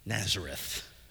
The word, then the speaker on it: Nazareth: and they would name that town Netzaret. We pronounce it Nazareth.